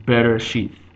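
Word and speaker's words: bereshith (בראשית): For example, in the beginning of Hebrew, of the Torah, I mean the beginning of the Torah, you have the word bereshith. Bereshith is pronounced, the B, the vowel underneath the B is a shva. It's be. It's just bereshith. That is a shva sound.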